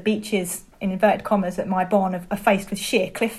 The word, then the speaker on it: Myebon: It's just, so Mai Bon is spelled M-Y-E-B-O-N.